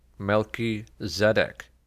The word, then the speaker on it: Melchizedek: The three priesthoods are of a patriarchal order, the Levitical order, and the order of Melchizedek, which is pronounced Melchizedek.